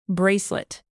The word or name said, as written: bracelet